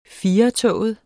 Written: FIRETOGET

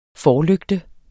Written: forlygte

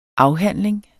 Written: afhandling

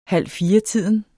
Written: halvfiretiden

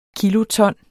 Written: kiloton

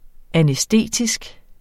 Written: anæstetisk

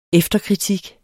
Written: efterkritik

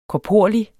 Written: korporlig

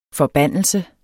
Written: FORBANDELSERNE

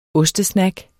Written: ostesnack

